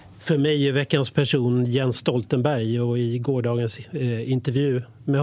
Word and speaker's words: Jens Stoltenberg: Jens Stoltenberg, så uttalas han på norska.